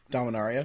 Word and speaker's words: Dominaria: I believe it's Domaria. Uh, Dom, I can never pronounce it. Domaria. Dominaria. Dominaria. Dominaria. Yeah. There's, there we go.